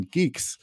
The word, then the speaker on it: Guix: Like I get to, in Geeks, which also I should note, it's not spelled like G-E-E-K-S, it's spelled G-U-I-X.